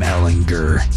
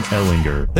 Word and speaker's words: Ellinger: It is Sam Ellinger. Hard G. Not a J. – Sam Ellinger.